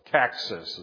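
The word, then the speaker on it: coccyx: I'm not sure I was pronouncing that word right before, but it's caxis is the way it's supposed to be pronounced.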